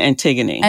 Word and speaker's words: Antigone: Do you say Antigone in English? Am I butchering this word? In English, we say Antigone.